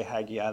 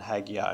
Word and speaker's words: Haggai: I would invite you to turn to the book of Haggai, which I grew up calling Haggai, and that's okay if you say Haggai, that's so much easier for me, but after extensive research this week, I saw Haggai much more often than Haggai, so there is a Hebrew pronunciation that I won't attempt for once that is not like either of those, but it's an important book.